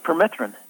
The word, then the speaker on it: permethrin: People think of spraying their pants and shoes with the permethrin.